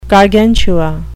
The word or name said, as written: gargantua